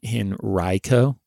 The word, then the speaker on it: Henrico: I said Henrico. Yeah. And that's not how they say it. It's, well, watch me probably. I'll get another email from Isaac. No, you tried to correct it. You still said it wrong. So let's just note that I don't know how to pronounce this county's name. But my best attempt, he spelled it phonetically as R-Y-E. So Henrico.